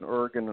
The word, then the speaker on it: Oregon: And I got to get three slaps in California, stop in Oregon, and a stop in Washington. So it should be pretty interesting, so I can ever travel again, so. But I'm just happy you pronounced Oregon right.